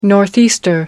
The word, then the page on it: northeaster: American English